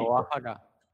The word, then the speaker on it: Oaxaca: Oaxaca, Oaxaca. – Acá hay que saber pronunciar las cosas, es Manuel.